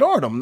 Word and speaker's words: Artem: I'm saying it right, Artem, no? – Yes.